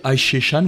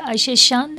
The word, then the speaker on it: Ayşe Şan: Ayşe Şan, vous nous présentez ? – Ayşe Şan, oui. Vous avez bien prononcé Ayşe Şan, les dîners.